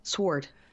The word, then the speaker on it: sword: You know, I have a very hard time pronouncing S-W-O-R-D. – So did Jenny McCarthy's friends on BFF Friday. Do you say sword? – Sword. – It's sword. – It's sword.